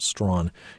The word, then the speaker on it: Strachan: By the way, if you go and look for the book, his last name is spelled Strachan, pronounced Strawn, Hugh Strawn.